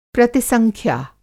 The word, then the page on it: प्रतिसंख्या: Hindi